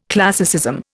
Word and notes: classicism: Indian

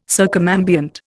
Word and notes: circumambient: Indian

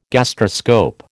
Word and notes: gastroscope: American English

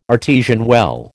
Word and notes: artesian well: American English

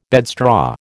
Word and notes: bedstraw: American English

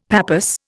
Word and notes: pappus: Indian

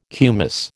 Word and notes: kumis: American English

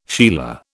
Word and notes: sheila: British English

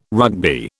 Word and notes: Rugby: British English

